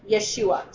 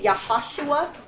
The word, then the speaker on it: Yeshua: In Hebrew and in the Greek, it is pronounced Yeshua. Some people say Yahashua because he's a type of Joshua, but the actual phonetics is Yeshua.